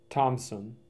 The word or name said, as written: Thomson